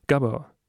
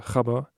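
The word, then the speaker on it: Gabber: Denn es wird bis zu 190 BPM hochgehen bei Gabba. Oder wie die richtige Aussprache wäre, Chabba. Denn das ist ein Wort aus einem niederländischen Dialekt.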